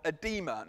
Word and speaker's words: edema: Most likely this man was suffering from edema. Now, I'm aware I'm speaking to a lot of medics here. Am I pronouncing that correctly? Edema? Yes. Got it right.